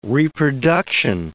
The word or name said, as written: reproduction